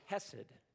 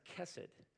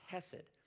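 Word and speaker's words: hesed (חסד): Let me write for you, this is the Hebrew word that we would transliterate, or the sound of it, and the word is hesed. Sometimes it's pronounced kesed with a ch, but we'll pronounce it for tonight as hesed.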